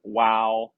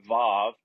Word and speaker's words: Waw: Okay, so today we get to the sixth section of Psalm 119, and it's the Hebrew letter WAW, W-A-W, or VAV, depending on how you pronounce it.